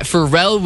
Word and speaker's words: Pharrell: First, we got Pharrell Williams and...